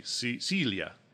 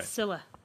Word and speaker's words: Scylla: Celia. Celia Chavaria. Anyway. – Scylla. Like Priscilla. – Like Priscilla. – Yeah. It's Scylla.